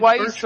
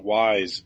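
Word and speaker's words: Weis: I've heard some people pronounce it Wise. – Which is not right.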